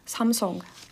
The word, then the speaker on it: Samsung: Kā izrunā Samsung? – Samsung. Ar O, jā. – Samsung. – Viņi tā kā... arī visur liek to uburtu, jā. – Bet īstenībā viņi izrunā kā O. Tāds garenāks O. Jā. – Tad nav nekāds Samsungs, ir Samsung.